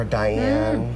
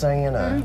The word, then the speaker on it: Diana: And she hated it when people would call her Diane. – Mm. Me too. – She's like, my name's not Diane, it's Diana. Mm, 3 syllables.